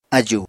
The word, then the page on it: ájùu: apatani